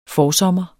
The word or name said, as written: forsommer